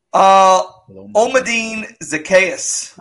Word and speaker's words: Zaccheaus: Omadine Zaccheaus.